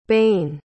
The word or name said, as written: baen